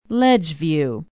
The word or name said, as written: ledgeview